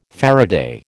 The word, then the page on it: faraday: American English